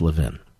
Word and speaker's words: Levin: Thank you very much, Mr. Levin. Have a good night. – It's Levin, but thank you, sir. We appreciate it. In Philadelphia, it's pronounced Levin. And that's where I grew up, Philadelphia.